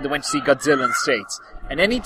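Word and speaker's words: Godzilla (ゴジラ): Well, he pronounces it Godzilla. – As in the proper Japanese way. – Yeah, exactly. Godzilla. Maybe that's the thing. – Maybe. – Well, there's no Z in the Japanese language, so...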